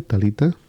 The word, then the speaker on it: Talitha: Talita McKenzie se escribe T-A-L-I-T-H-A, eso es el nombre, Talita, como suena, con H intercalada, entre la T y la A, y McKenzie, pues M-A-C, y luego K-E-N-Z-I-E.